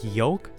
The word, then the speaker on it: yolk: If you look up how to pronounce yolk with an L, it spells it Y-O-W-K.